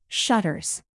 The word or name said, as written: shutters